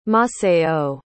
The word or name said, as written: maceió